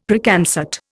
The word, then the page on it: Preconcert: Indian